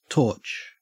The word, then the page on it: torch: Australian English